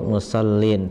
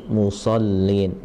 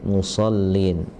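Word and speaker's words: مصلين: Nah itu huruf sod, jadi naik ya, jangan sampai musallin, tapi musallin, musallin ya.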